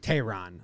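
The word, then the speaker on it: Tejerán: Tehran, okay. – Julio Tehran? – Tehran, yeah. – It's got a Y in there. I don't know. – There's a Y? – In the pronunciation.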